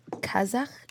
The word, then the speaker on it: Kazakh: So when you, so Russian and Kazakh, am I saying it right? – Yeah.